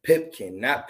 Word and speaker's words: Pipkin: Pipkin.